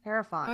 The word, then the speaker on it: Hierophant: How do you say this? Hierophant? Hierophant? Hierophant. Hierophant. – Hierophant.